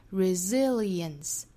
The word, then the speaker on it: resilience: 那 resilience 这个词它其实包含四个音节，我们知道四个音节其实就是四个节拍哒哒哒哒 resilience 在第二个音节我们做重读 resilience the power, the resilience that it built inside of you。